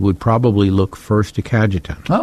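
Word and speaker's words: Cajetan: Today, the church celebrates the feast of Saint, and you may have to help me out on pronunciation, Kadajan? Kajetan. – Kajetan.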